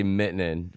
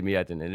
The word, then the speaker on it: Miettinen: I say Miettinen, but Dale Miettinen. That's the way you say it in Detroit. You know what I mean? If you're from Finland, you might say Miettinen.